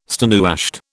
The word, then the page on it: stonewashed: British English